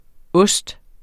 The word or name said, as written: ost